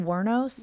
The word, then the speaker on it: Wuornos: Eileen Wuornos? – Yeah.